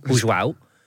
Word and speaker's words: João: Well, it's Portuguese, I think, isn't it? – Okay, well... – João. It's Juan in Espanol. João es Portuguese.